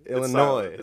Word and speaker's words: Illinois: Illinois. – It's silent. – Silent S. – S is for silent.